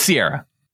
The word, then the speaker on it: Ciara: Well, which one is it? Is it Sierra or Ciara? – It's Sierra. – Like the mountains? – Yes.